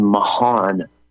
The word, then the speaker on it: Mohn: On the heels of that, this Justin Mahan, it's spelled M-O-H-N. It's John, take off the J and put an M there.